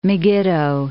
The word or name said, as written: Megiddo